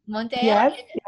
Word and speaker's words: Monteiro: Monterreo.